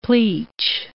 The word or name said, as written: pleach